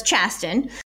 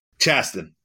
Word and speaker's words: Chasten: Hey, so listen, you were really, really amped up and excited about Transportation Secretary Pete Buttigieg and Chasten, I think that's how you pronounce his name, his husband, Chasten, becoming parents. – Yeah, so it's Chasten. – Chasten, got it.